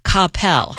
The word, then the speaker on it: Coppell: Is it Coppell, Texas, or is it Coppell? – Well, the first one you said is the closest. The second one sounds so wrong because it is. It's Coppell. Coppell.